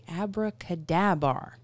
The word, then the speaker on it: AbracadaBar: We found a place that is open for fantastic beverages called the AbracadaBar.